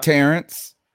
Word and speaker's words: Terrace: Terrace. Thank you. Yeah. Actually, I would have been saying that wrong forever. Terrace, stand out on the Terrace Marshall Jr. Yeah, that's it. I had to learn the hard way.